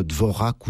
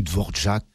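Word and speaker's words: Dvořák: Oui, tout à fait, avec Antonin Dvorak ou Dvorak, cela dépend au niveau de la prononciation de possibilités.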